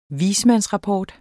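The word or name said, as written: dels ... dels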